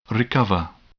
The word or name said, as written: recover